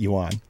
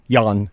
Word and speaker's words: yuan: And apparently, the first page that came up was saying that that's the wrong way to pronounce it, yuan. – Okay, what is it? I don't know. They didn't have one of those little audio files, and they said it's one syllable, yan.